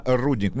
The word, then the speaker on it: рудник: Да все, все, все видели, но здесь впервые в нашей жизни мы почувствовали себя настоящими горняками и теперь знаем, как правильно произносится слово «рудник». Ребята, запоминайте, именно «рудник».